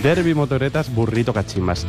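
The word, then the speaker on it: Derby Motoreta's Burrito Kachimba: Derby Motoretas Burrito Cachimbas.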